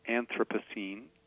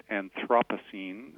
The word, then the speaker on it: Anthropocene: So I say Anthropocene, but my co-director, one of three, Jennifer Bachewal, has taken to pronouncing it Anthropocene, which is more how the British scientists from the Anthropocene Working Group pronounced it. So I think we're creating a kind of safe space for various interpretations and pronunciations of the word, but either are common, we've found.